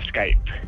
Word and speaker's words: Skype: y en algunos casos la E al final en inglés suena como I, entonces Skype, pero la, las raíces de la empresa no son americanas, son de Estonia.